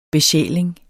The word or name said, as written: besjæling